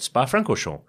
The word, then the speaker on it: Spa-Francorchamps: That is how you say it, Spa-Francorchamps,